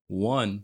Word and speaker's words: won: Juan is a Spanish guy's name. One. – Right. How do you say win? How do you say win like the past tense? – One. – One. W-O-N. Sounds like one. – O-N-E.